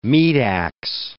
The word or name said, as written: meat-ax